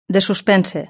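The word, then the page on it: de suspense: Spain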